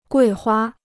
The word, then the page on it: 桂花: guì huā